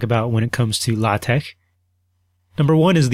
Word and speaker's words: LaTeX: He pronounced it LaTeX. He said, even though it looks like, sort of like the word latex, it's pronounced LaTeX.